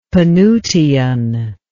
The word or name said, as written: penutian